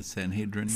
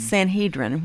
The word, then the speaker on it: Sanhedrin: The Sanhedrin. – Sanhedrin.